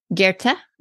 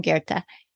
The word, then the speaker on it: Goethe: And you have this quote from, and I will admit to everyone that I might be butchering this name, but Goethe, I believe is how we say it.